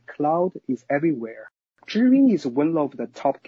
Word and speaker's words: ZhiYun: First, the company's name should be pronounced as ZhiYun in Mandarin, which means smart cloud in English literally.